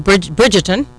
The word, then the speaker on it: Bridgerton: I mean, I tell you, there's no denying that Bridgerton, right, am I saying it correctly, is one of the most watched series on Netflix.